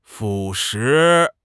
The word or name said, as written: 腐蚀